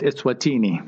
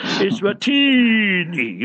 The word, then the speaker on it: Eswatini: Dawood Mondi says Eswatini. – Yeah, Eswatini. You see, he knows he can pronounce the word properly with tajweed in it. Eswatini. You must pull it a little bit, Mondi.